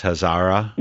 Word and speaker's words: Hazara: One of them that struck me was a story about what happened at, I think it's pronounced Hazara. – Yes, yes.